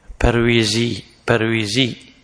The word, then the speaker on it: برويزي: وهذا مخالف، هي تنطق باللغة الأردية برويزي، هم يقولون أنفسهم برويزيين، لكن بالعربي تكتب والجماعة البرويزية، فيه برويزية في يا ساقطة بعد الواو.